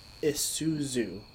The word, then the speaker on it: Isuzu: And it's pronounced Isuzu. The Z is He said second.